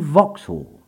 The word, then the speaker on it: Vauxhall: In time, London pronunciations changed Fawkes Hall to Vauxhall, and that region has been known as Vauxhall ever since.